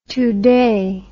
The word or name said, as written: Tudeh